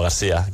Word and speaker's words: García: Joan García o Joan García? – ¿Cómo es? – Bueno, García, català. – García, Joan sí, García, sí. Perfecto.